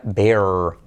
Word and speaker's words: bear: Oso se dice, se escribe bear, y fíjate que se pronuncia bear, bear.